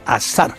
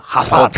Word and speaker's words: Hazard: Se pronuncia Hazard. No digáis como dice Pidal, Hazard.